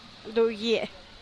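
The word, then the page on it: dugie: Teotitlán del Valle Zapotec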